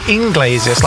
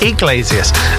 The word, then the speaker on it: Iglesias: Some people say Inglesias, like there's an N after the I. No, there's actually not. It's Iglesias.